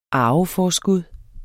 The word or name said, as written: arveforskud